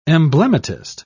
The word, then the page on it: emblematist: British English